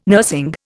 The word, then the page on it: nursing: Indian